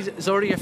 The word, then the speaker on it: Zorya: How many Zorya fans will be here tonight? And have I even pronounced it correctly? – You did well there. – Yeah, you did well. Zorya Luhansk.